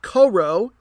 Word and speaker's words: Kourou: Le Geldar, they are in the city of Kourou, if I'm saying that right, K O U R O U, about 25,000.